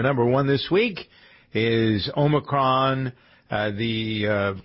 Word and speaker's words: Omicron: You've nailed that pronunciation. – It took you a week. For those of you who didn't hear, I was calling Omicron. – Omicron, like a tech company, yeah.